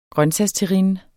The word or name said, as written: grøntsagsterrine